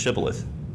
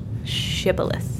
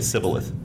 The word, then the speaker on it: shibboleth: Shibboleth. – Yeah, so the SH sound is not there for every language, right? So the people who they were trying to capture would say Sibboleth And so there's Shibboleth.